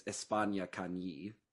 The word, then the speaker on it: España Cañí: I'm sure I butchered the pronunciation of that. – Based on the punctuation, I would assume it's España Cañí. – Sure. – Sounds pretty similar. – Espana versus España.